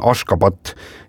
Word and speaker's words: Ashgabat: Ja selle pealinna tänapäevane õige kirjapilt ja hääldus on Ashgabat ja siis 1991.